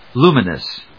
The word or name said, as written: luminous